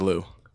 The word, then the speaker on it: LUU: Lou? – The correct pronunciation is Lou.